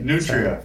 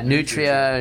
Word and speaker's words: nutria: I know I don't pronounce that right. – Nutria. – So, yeah, Nutria.